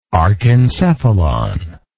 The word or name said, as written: archencephalon